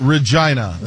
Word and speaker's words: Regina: It's Regina. That's the way it's pronounced.